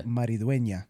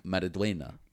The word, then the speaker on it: Maridueña: By the way, his name is his last name, the way you pronounce it, is, yeah, Maridueña. – Maridueña. – Maridueña. – Ah. – Yeah, with the ña.